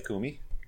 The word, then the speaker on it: Kume: So we are here with Slave Kume.